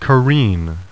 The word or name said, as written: CAREEN